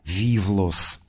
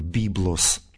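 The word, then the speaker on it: вивлос (βίβλος): Но если мы к греческому тексту стихиры обратимся, мы там прочитаем выражение «и вивлос», «ту логу тис зоис», «вивлос» – «книга». В классическом произношении античном это слово читается как «библос», отсюда «библия», в позднем византийском чтении «вивлос»,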